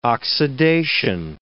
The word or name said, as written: oxidation